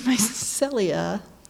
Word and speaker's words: mycelia: But you know, these mycelia, if I'm pronouncing that right, am I? – You got it.